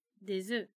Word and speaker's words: des œufs: Bom, œuf com S vira E un œuf, des œufs.